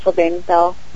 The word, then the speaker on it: Sylvain Bell: His name is Sylvain Bell. I think he likes to go by S.V. Bell, but okay. – He's French-Canadian, so it's Sylvain Bell.